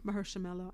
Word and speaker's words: Mahershala: I can't pronounce his name. – Mahershala. I think that was a good pronunciation.